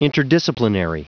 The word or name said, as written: interdisciplinary